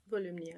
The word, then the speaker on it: Volumnia: what you're saying about Volumnia, did say that right? – Volumnia, yes.